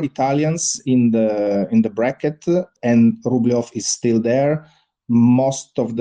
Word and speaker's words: Rublev: How do you say his surname in Russia correctly? – Rublev. Rublev. Rublev.